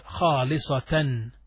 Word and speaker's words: خالصةً: khalisatan.